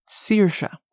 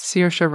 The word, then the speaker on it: Saoirse: I'm going to pronounce that name that I didn't know how to pronounce before. It's pronounced Saoirse. – Oh, yes. – Saoirse Ronan.